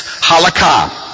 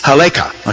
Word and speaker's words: Halakha: That is Halakha. If you're Ashkenazic, if you're Sephardic, okay? If you're Ashkenazic, it's Haleka, okay? Or Laka, okay? And so the accent is on a different syllable, but it's the same word.